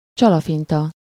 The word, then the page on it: rusé: France; /ʁy.ze/